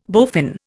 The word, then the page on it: bowfin: Indian